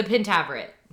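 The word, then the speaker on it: Pentaverate: And I still second guess it now, though, because we said it wrong for so long. But it's the Pentaveret.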